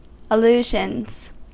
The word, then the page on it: allusions: American English